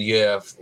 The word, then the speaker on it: Jerv: And Jerv, as is the correct pronunciation, I think, isn't it? – Yeah.